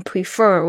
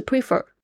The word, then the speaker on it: prefer: Number 10, prefer, P-R-E-F-E-R, prefer, 宁愿或者是偏好，这边 prefer, 我有听到很多的同学会念成 prefer, 不是 prefer, prefer, 重音在第二个音节上面哦。